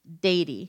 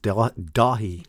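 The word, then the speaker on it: Dáithí: D-A-I-T-H-I-F-A-D-A. Dathy. – I'm going with a silent H. So I'm going to say Dady. – Maybe we should do the silent T, though, from before. – Dahi. – Dahi? Dahi? Okay, what is it? – The closest with Dahi.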